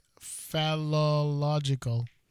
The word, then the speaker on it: Phallological: It's Iceland's how do you pronounce this? Phallological Museum. – Like phallic? – Like phallological. Phallological.